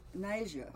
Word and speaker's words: Nyjah: And you might remember, you might pronounce his first name correctly. – Nyjah? – Nyjah.